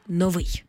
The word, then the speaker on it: новий: В усному мовленні раз по раз чуємо «новий». Колись це слово справді мало два наголоси, але вже давно наголос на першому складі вважається ненормативним, тому слід казати «новий».